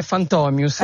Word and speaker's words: Fantomius: Io lo pronuncio Fantomius, invece Fantomius qua. – Perché? Sì, allora la pronuncia è Fantomius, ecco.